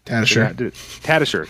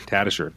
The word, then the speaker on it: Tatasciore: And I turn my head, and there's Fred Tatasciore. – Tatasciore. – Tatasciore.